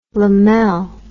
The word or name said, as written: lamell